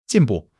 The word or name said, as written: jin4 bu4